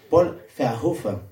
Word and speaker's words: Paul Verhoeven: Paul Verhoeven, cuando en realidad, bueno, debería pronunciarlo bien, es Paul Verhoeven, dicho en holandés, porque sé un poco de holandés, no preguntes por qué.